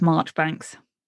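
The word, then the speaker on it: Marjoribanks: Majori Banks, pronounced Marchbanks.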